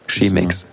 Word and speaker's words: Chemex: Heißt das Chemex? Ja. Wird es auch so ausgesprochen? Aha. Okay. Chemex.